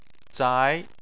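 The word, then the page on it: zai: tone 2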